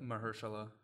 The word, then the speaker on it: Mahershala: I'm going to mispronounce this, but Mahershala.